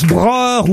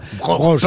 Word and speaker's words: Broch: Alors, je sais pas si on prononce Broer ou Broche. – Broche. – Parce que moi, parfois, ça dépend s'il y a un umlaut sur le O. – Oui. – Il n'y a pas de umlaut sous le O.